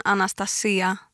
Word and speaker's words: Anastasia: Om jag ska på grekiska så säger man Anastasia med betoningen på i-et då.